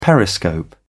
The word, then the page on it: periscope: British English